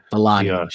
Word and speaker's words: Vellani: I know I kept mispronouncing her last name. Iman Vellani.